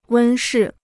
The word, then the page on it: 温室: wēn shì